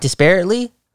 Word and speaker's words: disparately: Did I say it right? Disparately? – Disparatively? – I don't see the V there,